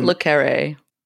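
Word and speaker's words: le Carré: Le Carre.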